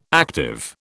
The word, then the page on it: active: British English